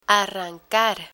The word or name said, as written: arrancar